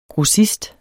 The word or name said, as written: GROSSISTER